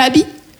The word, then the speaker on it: Aby: Pour les Américains c'est Abby. Et pour ma mère c'est Eh viens ici, voilà. Non, comme je vous ai dit, Abby c'est mon prénom, Abby, A-B-Y, trois lettres, deux syllabes.